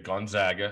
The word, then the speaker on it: Gonzaga: And I thought Sean Farnham did a great job coming on after with Scott Monday night and just talking about the idea of Gonzaga Gonzaga, how the right way to say it.